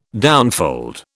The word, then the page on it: downfold: British English